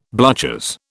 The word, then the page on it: bluchers: British English